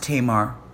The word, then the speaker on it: Tamar: It's pronounced Tamar. – Tamar.